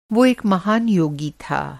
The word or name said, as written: योगी